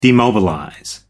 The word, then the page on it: demobilize: American English